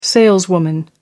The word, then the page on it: saleswoman: American English